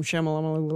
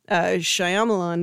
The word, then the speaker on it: Shyamalan: Night Shyamalan, I'm going to try to say his name right instead of calling him Shyamalan like you did. Shyamalan movie that we all really saw.